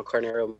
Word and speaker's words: Carneiro: My last name is spelled C-A-R-N-E-I-R-O. My Facebook is Bruno Carneiro Motorsports as well.